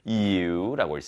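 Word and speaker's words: ewe: 자, 이거 발음 특이하죠? you라고 읽습니다. eu입니다. 그래서 you. 맞죠? 발음이 좀 특이합니다.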